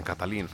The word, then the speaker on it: Katalin: Katalin säger vi. Jag har aldrig hört något annat än Katalin faktiskt.